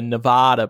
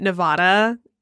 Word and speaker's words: Nevada: Unless it's like Nevada, where they get really upset if you say it wrong. – Yeah. – I fixed myself on that one really quick. I used to be a Nevada person, but it's Nevada.